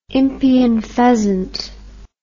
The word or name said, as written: impeyan pheasant